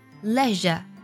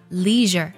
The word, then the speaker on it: leisure: 再来分享最后一个单词，这个单词呢拼作 leisure，那么在英式的发音当中呢它读作 leisure，它指的是闲暇或是休闲的意思，那么在美式的发音当中呢它却读作了 leisure。